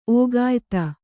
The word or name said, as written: ಓಗಾಯಿತ